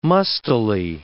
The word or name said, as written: mustily